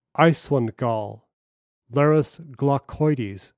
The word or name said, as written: Iceland Gull